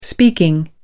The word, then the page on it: speaking: British English